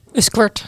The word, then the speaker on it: Uskert: Spreek je dat goed uit? Uskert? – Uskert, ja.